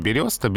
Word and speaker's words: берёста: Конечно, именно поэтому произношение береста. Береста — это верхний слой коры березы, Береза йо и береста йо.